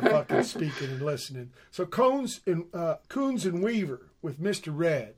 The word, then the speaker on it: Kouns: How do you pronounce this? Kouns? – Kouns. – Or Kouns. Okay. Well, you know English. You don't know until you hear the guy say it, right?